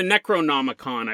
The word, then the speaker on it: Necronomicon: I mean, if anything comes close to the Necromonon, it's this thing or the Necronomicon.